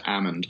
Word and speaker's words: Almond: And just on the other side of the River Amund, which is spelled like almond, but it's pronounced Amund, is a really cool place called Eagle Rock.